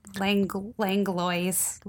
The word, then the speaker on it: Langlois: It's spelled L-A-N-G-O-I-S. Is that right? – L-A-N-G-L-O-I-S. Langlois.